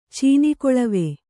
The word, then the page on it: ಚೀನಿಕೊಳವೆ: cīni koḷave